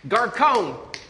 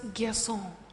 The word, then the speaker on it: garçon: Garcon! Garcon! – Dad, it's pronounced garçon.